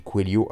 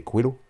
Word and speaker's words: Coelho: D'ailleurs, je sais pas pourquoi je dis Coelho alors que c'est Coelho, on prononce, non Je suis pas très très fort en brésilien, portugais, je sais même pas ce qu'il est.